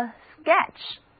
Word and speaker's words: sketch: 还有一个单词叫做 sketch， S-K-E-T-C-H，意思是略图、速写。字母组合 P-C-H 读作 ch。